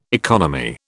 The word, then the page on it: economy: British English